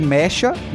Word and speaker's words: Mecha: Algumas pessoas chamam de Mecha, mas eu consultei, a pronúncia correta é Mecha.